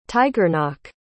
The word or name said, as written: tigernach